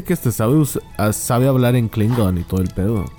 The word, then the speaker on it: Klingon: Se escribe con K de Cligón. No, Klingón. Ah, cabrón, ¿cómo Klingon. – Klingon.